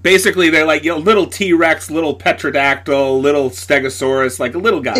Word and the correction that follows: pterodactyl: Did you just fucking say Petrodactyl? – Pterodactyl. – You fucking heathen.